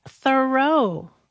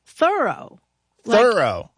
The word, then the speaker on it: Thoreau: All this time, I thought his name was pronounced Thoreau, but no. – How is it pronounced then? – Thoreau. – Like, as in I'm thoroughly did it. – Yeah, or I'm thoroughly surprised that it's pronounced that way.